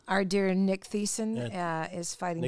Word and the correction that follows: Thiessen: Nick Tyson. – Tyson. I know. I always say Thiessen because it's pronounced that way. Well, it's spelled with a T-H.